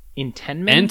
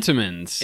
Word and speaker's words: Entenmann's: I don't want to show you too much of this because the picture, I don't know, but like Entenmann? – Entenmann's.